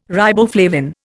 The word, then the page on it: riboflavin: Indian